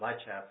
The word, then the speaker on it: Lecher: a little town called Lecha. They pronounce it Lecha, but it's spelled Lecher. – Oh, really? – Yeah, E-R. But that's how the Southerners do it.